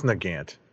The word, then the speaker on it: Nagant: Is that how you pronounce that? War? – I think it's Nagant.